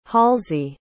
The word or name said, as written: halsey